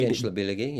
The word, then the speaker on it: Gençlerbirliği: So the other one was in a Turkish league game between Beşiktaş and, unfortunately, a team I can't pronounce, but Gençlerbirliği replayed... – Gençlerbirliği, yeah.